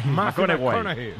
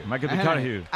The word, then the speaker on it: McConaughey: Me fichó, le gusta que hay que ir corrigiendo la pronunciación. – McConaughey. McConaughey. Bueno, pues el Matías.